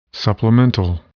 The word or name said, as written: supplemental